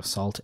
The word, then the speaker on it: Sault: Salt, Sault, is how you say that.